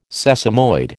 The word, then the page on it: sesamoid: American English